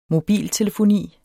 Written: mobiltelefoni

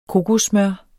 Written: kokossmør

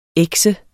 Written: ekset

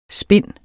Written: spind

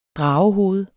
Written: dragehoved

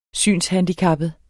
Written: synshandicappet

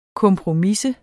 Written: kompromisse

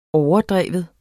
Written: overdreven

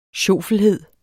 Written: sjofelhed

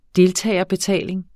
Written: deltagerbetaling

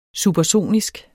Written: supersonisk

